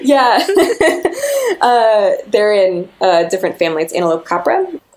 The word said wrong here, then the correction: Antilocapra: Antilocapra americana.